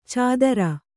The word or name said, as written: ಚಾದರ